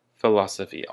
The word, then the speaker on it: philosophy: Philosophy, also with the emphasis on the law.